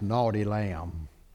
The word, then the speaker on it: No T. Lamb: No T. Lamb. Undeservedly pronounced Naughty Lamb.